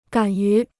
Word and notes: 敢于: gǎn yú

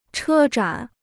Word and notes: 车展: chē zhǎn

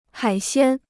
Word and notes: 海鲜: hǎi xiān

